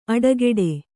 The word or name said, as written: ಅಡೆಗಟ್ಟು